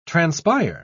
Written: transpire

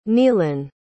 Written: nealon

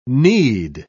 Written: kneed

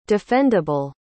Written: defendable

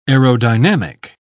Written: aerodynamic

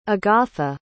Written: agathe

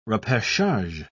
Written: repechage

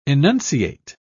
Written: enunciate

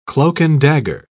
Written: cloak-and-dagger

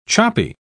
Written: choppy

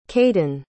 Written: caiden

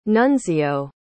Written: nunzio

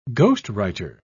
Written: ghostwriter

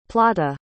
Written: plata

